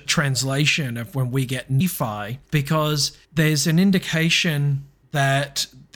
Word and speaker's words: Nephi: But what I would say is the R, the final R in NFR, in the Egyptian form was pronounced as an I or a Y sound. So NFI or NFY, Nephi. – Nephi. – And if you have a look at the Hebrew, the name of Nephi is spelled N-P-Y, Nephi. But the P is the equivalent to an F, so Nephi.